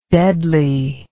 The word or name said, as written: deadliness